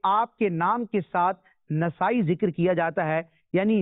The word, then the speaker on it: نسائی: بعض لوگ اس لفظ کو نسائی پڑھتے ہیں امام نسائی، تو علماء کرام نے یہاں ذکر کیا کہ اس کا درست تلفظ اور اعراب یہ ہے کہ نون اور سین دونوں پر زبر آئے گا تو نسائی نہیں ہوتا بلکہ نسائی ہوتا